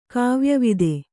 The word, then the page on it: ಕಾವ್ಯವಿದೆ: Kannada